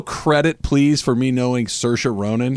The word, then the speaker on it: Saoirse: It's spelled so weird. – It's got like I's and O's, and it's Saoirse.